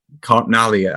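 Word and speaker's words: Lipkin Gorman: I've always pronounced it Lipkin, Gorman and Carpnail in my head. – I did until I was corrected. Carpnally, I think he was an Italian gentleman.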